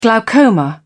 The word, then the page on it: glaucoma: British English